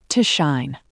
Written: to shine

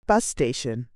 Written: bus station

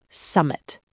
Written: summit

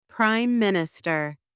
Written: prime minister

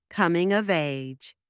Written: coming of age

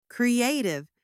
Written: creative

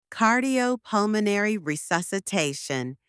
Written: cardiopulmonary resuscitation

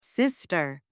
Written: sister

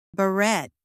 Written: barrette

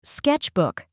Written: sketchbook

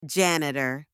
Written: janitor

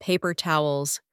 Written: paper towels

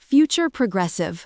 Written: future progressive

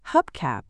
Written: hubcap

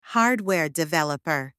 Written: hardware developer

